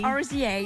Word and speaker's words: RZA: RZA.